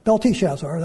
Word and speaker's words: Belteshazzar: Their real names are, Daniel's Belteshazzar, that's the right way to say it, Hananiah, Mishael, and Azariah.